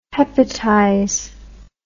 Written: hepatize

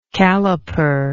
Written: calliper

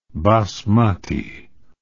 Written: basmati

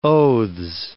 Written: oaths

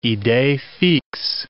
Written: idees fixes

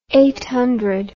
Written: eighth rest

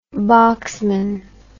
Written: boxman

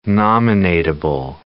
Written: nominatable

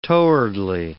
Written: towardly

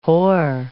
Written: whore

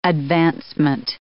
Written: advancement

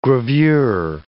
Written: gravure

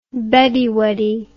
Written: beddie weddie

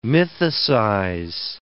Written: mythicize

